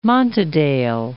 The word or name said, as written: Montadale